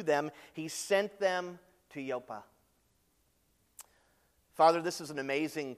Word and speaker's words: Joppa: And now send men to Joppa, that's how it's pronounced, Joppa, and bring one Simon who is called Peter.